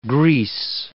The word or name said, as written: grease